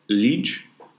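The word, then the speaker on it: Liège: We're in Liege, I believe is the way you say it, in Belgium.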